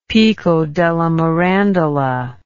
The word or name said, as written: Pico della Mirandola